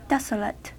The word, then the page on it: desolate: American English